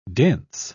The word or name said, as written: dense